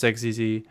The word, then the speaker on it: Xexizy: I tend to gravitate towards Xexizy. But it's really, it's quite, you know, postmodern, really. There is no true way to pronounce it. It's however you interpret the pronunciation is how it's pronounced.